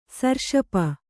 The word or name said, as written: ಸರ್ಷಪ